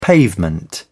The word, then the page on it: pavement: British English